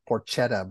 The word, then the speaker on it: porchetta: Can you explain, because I don't know if everyone knows what porchetta is, and it's like people it's spelled like porchetta, but it's pronounced porchetta.